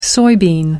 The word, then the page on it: soybean: British English